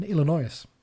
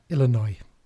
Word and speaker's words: Illinois: There are seven other places around the world called Exeter, including towns in Canada, Pennsylvania, and Illinois. Illinois. Thank you for correcting my pronunciation there, Mr. I've been to America a few times, but there you go.